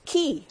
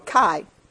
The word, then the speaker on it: ki: So I've got some other things on here, but massage therapy, you know, and this ki or kai, it's pronounced both ways.